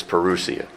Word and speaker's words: parousia: The Lord's coming, parousia. I think that's how you pronounce it. Is that right, Mark? All right. We'll ask the Greek scholar over there, and he confirms that it's parousia.